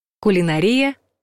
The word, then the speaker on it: кулинария: Кулинария — также правильный вариант. По старой норме в этом слове ударение падает на третий слог — кулинария, от латинского «culinarius» — кухонный.